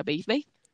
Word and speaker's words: Bade Bade: lo adapté a mi gusto y por eso se escribe Bade Bade y se pronuncia Bade Bade, y así es como surge mi, mi nombre, artístico, por así decirlo.